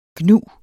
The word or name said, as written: gnu